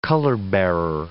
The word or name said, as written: color-bearer